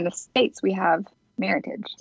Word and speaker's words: Meritage: So they kind of created Meritage, which is merit and heritage. So it's always pronounced Meritage.